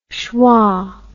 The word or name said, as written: sheva